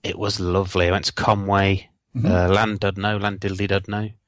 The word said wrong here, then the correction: Llandudno: I think you'll find that's pronounced Llandudno. – Right. – Two L's is in Welsh.